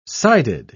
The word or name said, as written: sided